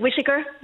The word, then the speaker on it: Whiteacre: It's actually spelled like Whiteacre. – Yes. – Yeah, but it's pronounced Whittaker. Yeah.